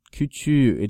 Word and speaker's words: küçüğü: And so we have to change that K. – Into a yumuşak G. – Right, to that silent G. – Evet. – Küçüğü. It's just küçük with an accusative.